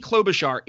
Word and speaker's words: Klobuchar: It is John McAfee. It is Amy Klobuchar. It is Pete Buttigieg.